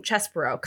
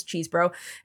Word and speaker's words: Chesebro: First, let's start with the news that broke on Friday when Kenneth Chesbro, apparently that's how you say his name, Chesbro, kind of a bummer. I liked it better when it was Cheesebro.